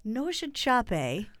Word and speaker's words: Nossa Chape: Well, it's spelled N-O-S-S-A-C-H-A-P-E. – Say Nasa Chape. It's Brazilian. It's Portuguese. So, or maybe I was definitely going for Nasa Chape. Nosha Chape. – Yeah, that's probably it.